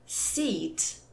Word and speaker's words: seat: Como exemplos, a palavra sit, S-I-T, sentar, e seat, S-E-A-T, assento. Né? Então, sit e seat têm sons diferentes, é um minimal pair.